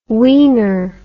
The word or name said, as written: weiner